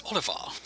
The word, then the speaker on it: Olivar: Gotta say it like they do. It's Olivar.